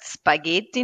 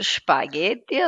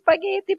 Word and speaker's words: Spaghetti: man sagt Spaghetti, nicht Spaghetti, so, das ist auch so, ich ein bisschen nehme ich die Menschen auf den Arm, also das ist Spaghetti, bitte mindestens das richtig aussprechen.